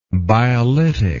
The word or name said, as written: biolytic